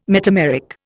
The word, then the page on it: metameric: Indian